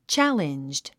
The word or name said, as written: challenged